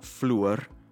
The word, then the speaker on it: flour: So she talks about fluor, for flour. – So yeah. to use an oo sound for what for me is an ow sound.